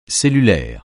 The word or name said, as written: cellulaire